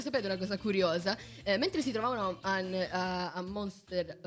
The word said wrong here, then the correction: Montserrat: oddio, come si pronuncia, ragazzi? Montserrat. Grazie.